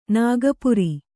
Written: ನಾಗಪುರಿ

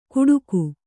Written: ಗುಡುಗು

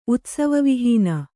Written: ಉತ್ಸವವಿಹೀನ

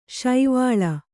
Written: ಸೇವಾಲ

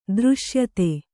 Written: ದೃಶ್ಯತೆ

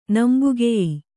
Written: ನಂಬುಗೆಯೀ

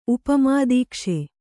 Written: ಉಪಮಾದೀಕ್ಷೆ

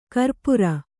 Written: ಕರಪುರಿ

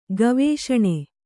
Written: ಗವೇಷಣೆ

